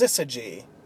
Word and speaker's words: syzygy: You guys were actually really close. It's pronounced Syzygy. Syzygy.